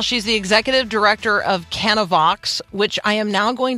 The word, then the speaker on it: Canavox: It's Canavox.